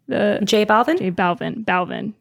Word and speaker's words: Balvin: Balvin? Is that how you say his name? – J Balvin.